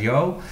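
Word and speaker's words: Yeoh: En die hebben dus nu een film gemaakt met eh, Michelle en, Jo, of Jeo, hoe spreek je dat uit? – De Amerikanen spreken het uit als Jo.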